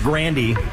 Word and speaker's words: Grande: So I saw an interview with Ariana Grande, and she's like, yeah, everybody pronounces my last name wrong. It's actually pronounced Grandy.